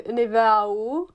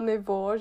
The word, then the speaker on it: Niveau: No, jako je pravda, že němčina používá hodně francouzských slov, takže jako třeba Niveau, vždycky já jsem se to učila jako to, jak se to píše, ale nevěděla jsem, jak se to pořádně vyslovuje, pak teda jsem zjistila, že je to Niveau,